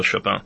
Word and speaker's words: Chopin: So we fight about whether it's Chopin in Poland or Chopin in Paris. In Hebrew, we say Chopin, closer to what the Poles say.